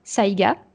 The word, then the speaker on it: saïga: Ok, bah, par exemple, c'est parce que je voyais récemment le gilet, euh, c'est Saiga, tu me dis si je prononce bien. – Oui, c'est ça, c'est celui dont je parlais juste avant.